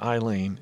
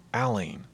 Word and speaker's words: Allene: It was, well, no, her name's not Eileen, it's Aline. Neither name is very common, but it's spelled A-L-L-E-N-E as opposed to A-I-L-E-N-E.